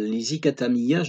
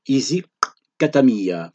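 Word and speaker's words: Isicathamiya: Alors je prononce mal l'Isikatamiya, je devrais faire claquer ma langue sur la lettre C en langue zoulou, c'est ainsi que l'on procède. Je devrais dire Easy katamiya ».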